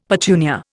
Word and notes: petunia: Indian

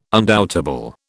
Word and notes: undoubtable: British English

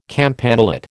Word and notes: campanulate: American English

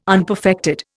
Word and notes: unperfected: Indian

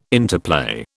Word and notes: interplay: British English

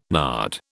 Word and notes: nard: British English

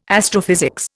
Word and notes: astrophysics: Indian

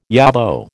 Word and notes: yobbo: American English

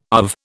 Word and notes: UV: British English

